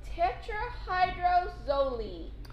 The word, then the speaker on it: tetrahydrozoline: I believe it is pronounced tetrahydrozoline. – Well, excellent job, yes.